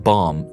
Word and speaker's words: BOM: BOM, pronounced bomb, stands for Bill of Materials.